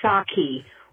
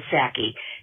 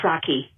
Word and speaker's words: Psaki: It's Jen Psaki with a soft A instead of Psaki. Jen Psaki. Bye-bye.